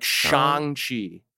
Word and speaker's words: Shang-Chi: So I actually, it is like, I think it's more like Shang. – Shang? – Like a long A, like Shang-Chi.